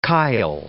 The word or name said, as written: chyle